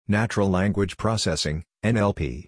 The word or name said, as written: Natural Language Processing (NLP)